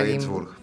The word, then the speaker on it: Ginzburg: de Natalia Ginzburg.